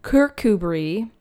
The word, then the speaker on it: Kirkcudbright: We're on Little Ross Island in Kirkcudbright, Scotland, which I just learned yesterday is how you pronounce it. But it's spelled like Kirkcudbright. Bright. And it's Brie.